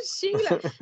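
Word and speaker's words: Shelagh: Sheila. It's actually spelt, I'm hoping I'm pronouncing it right. It's actually spelt S-H-E-L-A-G-H. I'm assuming that's what, it's not like Sheila, Australia. But yeah, Sheila wants to see the back of them, I think.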